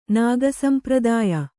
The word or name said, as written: ನಾಗಸಂಪ್ರದಾಯ